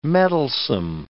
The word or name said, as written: mettlesome